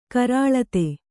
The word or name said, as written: ಕರಾಳತೆ